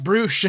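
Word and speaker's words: Brüush: Brouche, Alex. B-R-U with an umlaut. U-S-H. – Why, yeah. – I love it. And there's like a note in there that's like, it's pronounced Brouche.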